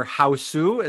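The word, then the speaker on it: Hausu: So House or Houseu, is that how I pronounce it? – Yeah, Houseu, I guess, in Japanese.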